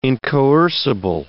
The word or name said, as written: incoercible